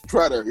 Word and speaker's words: Tretter: Yeah, it's spelled T-R-E-T-T-E-R. So he... – Like Trader or Treader? – Treader, yeah, right. There you go, John. Thanks for the help on the pronunciation.